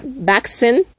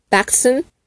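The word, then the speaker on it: wachsen: Ejemplo, la, palabra que se escribe se escribe S-E-C-H-S, e, pero se va a pronunciar sex y significa seis. O la letra Empieza con W, W, A, C, H, S, E, N. Se va a pronunciar Baxen, Baxen, y significa crecer.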